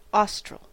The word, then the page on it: austral: /ˈɔ.stɹəl/; /ˈɔː.stɹəl/